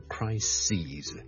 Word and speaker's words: crises: 留意个发音啊串字當然是不同的發音是不同的 crisis 和 crises